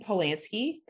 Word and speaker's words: Polanski: Roman Polanski, I think I said it right.